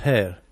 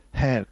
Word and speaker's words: Hair: Dillo, voglio sentire la tua pronuncia. – Hair. Hair, con l'H. Sì, eh? Che non è come capelli, eh? – No, eh, bravo, quello, quello, sì, eh, quello un po' quello è H-A-I-R, E, e no?